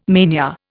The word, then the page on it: mania: Indian